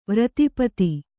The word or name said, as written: ವಾರಿದಪಥ